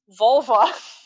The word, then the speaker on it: volva: Vulva. Not the female part. It's literally V-O-L-V-A. So it's like a Volvo.